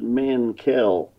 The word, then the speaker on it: Mankel: Am I pronouncing that right? – Well, I would just pronounce it Mankel. – Mankel. – Just equal accent on both the syllables.